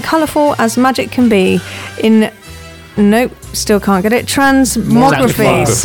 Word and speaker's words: Transmogrify: Transmogrify. – Oh, okay, Transmogrify.